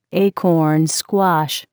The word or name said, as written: acorn squash